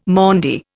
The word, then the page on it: Maundy: Indian